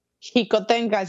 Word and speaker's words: Xicoténcatl: Y qué riqueza tan, tan hermosa la que pues tú has vivido allá en psicotencal Por cierto, psicotencal se escribe con X la primera, ¿verdad? – Jicotencal, se pronuncia como, como, como, como J, como México, Jicotencal.